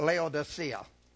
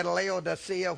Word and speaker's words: Laodicea: Now, you can either say Laodicea, which is the only way that I've ever pronounced it, but I have a new app on my phone. And Laodicea is either Laodicea or Laodicea. I like Laodicea.